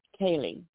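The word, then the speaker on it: ceilidh: But I looked this up, and this is pronounced Kaylee. That means dance. That's Scots for dance.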